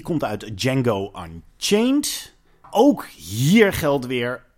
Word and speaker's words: Django: Django, the D is silent, bij een kampvuur zitten.